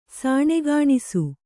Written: ಸಾಣೆಗಾಣಿಸು

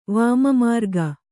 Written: ವಾಮಮಾರ್ಗ

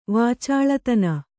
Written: ವಾಚಾಳತನ

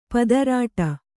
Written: ಪತ್ರೊಡೆ

